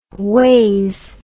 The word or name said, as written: wase